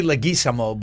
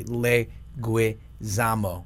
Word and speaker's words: Leguizamo: Well, I miscorrectly pronounce it Leguizamo. It's really Leguizamo, but nobody could ever say that. They don't understand where the U came from, so I started doing it phonetically, Leguizamo.